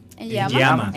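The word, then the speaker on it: Enyeama: Hay que mencionar el excelente trabajo del portero nigeriano Enyema. ¿Lo pronuncié bien? – En Yama. – Vincent en Yama.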